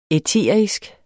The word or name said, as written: æterisk